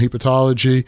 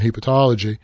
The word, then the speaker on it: hepatology: And as a fellow, he received extensive training in all aspects of gastroenterology and hepatology. Am I pronouncing hepatology right, or is it hepatology? – Hepatology. – Hepatology. – That's right.